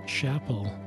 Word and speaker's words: Chappell: So it's M-E-G-G-A-N, last name Chappell, C-H-A-P-P-E-L-L. Lots of doubles. – Chappell. – Yep. – Okay. – Like a church chapel.